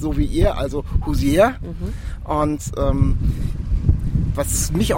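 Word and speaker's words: Hozier: Also es, ist, es wird H-O-H und dann S-I-E-R ausgesprochen, also Hosea.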